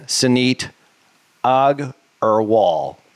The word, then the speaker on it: Suneet Agarwal: None other than Suneet Agarwal.